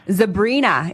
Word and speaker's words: Zebrina: Zebrina is the name of that one.